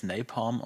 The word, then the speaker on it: Napalm: Das wird aber eher als Napalm ausgesprochen dann im Amerikanischen.